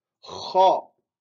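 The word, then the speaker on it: Kha (خ): Et là, on prononce avec, comme si c'était un rugissement. Kha. Et avec un son grave.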